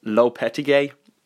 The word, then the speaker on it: Lopetegui: you pronounce his name a bit weird, it's Lopetegui, the Spanish manager.